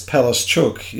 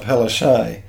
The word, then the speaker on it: Palaszczuk: The correct pronunciation of Palaszczuk? – He says, as a Polish person... – Is Palaszczuk. – It's Palaszczuk, yeah, not Palaszczuk.